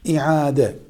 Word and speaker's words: iade: İade, ibadetin iade edilmesi. Kelimenin aslı iadedir. Ama biz Türkçe'de bunu iade diyoruz. İade. aynı harfinin telaffuzu zor olduğundan Türkçe için ibadeti iade etmek.